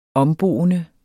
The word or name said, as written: omboende